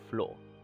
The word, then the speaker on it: Floor: But the singer on this song is Floor. Like the floor that we're sat on.